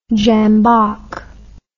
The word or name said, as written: jambok